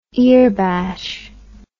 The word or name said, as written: earbash